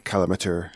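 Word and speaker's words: Calamateur: It's the word amateur on its own, and then C-A-L before that. So C-A-L amateur, Cal amateur. – Okay. – Or Calamature, however you want to pronounce it.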